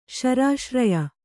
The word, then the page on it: ಶರಾಶ್ರಯ: kannada